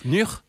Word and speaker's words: Nûr: Je ne sais pas comment on prononce, c'est le NÛR. – NÛR. – Okay. – Dis ce que tu veux en fait. – On écrit ça N, U accent circonflexe, R.